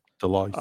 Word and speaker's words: Deloitte: Deloitte.